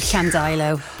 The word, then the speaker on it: Llandeilo: I was going to say excellent pronunciation of Llandeilo.